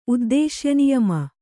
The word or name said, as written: ಉದ್ದೇಶ್ಯನಿಯಮ